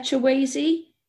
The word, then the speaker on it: Chiweshe: You might have to help me pronounce this name, Paul. Stella Chiwazi, is that right? – That's it, yeah.